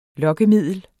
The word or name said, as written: Lokkemiddel